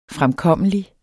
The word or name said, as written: fremkommelig